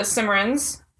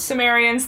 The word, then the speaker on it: Cimmerians: So they can't evac the Cimmerians. That's not right. I mispronounced it. – Cimmerians? – Cimmerians, thank you.